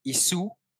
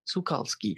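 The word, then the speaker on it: Szukalski: Yeah, it's Sukalski. – Sukalski. – It's a silent Z, if I'm not wrong. – Sukalski.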